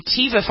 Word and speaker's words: Teva: You want to bring us up to date on this settlement, the pharmaceutical, what is it, Teva, is that how it's pronounced, Teva? pharmaceuticals? – Yeah.